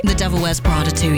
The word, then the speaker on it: The Devil Wears Prada 2: It's a new bit of music from The Devil Wears Prada 2 film, am I saying that right? – The Devil Wears Prada 2, yes.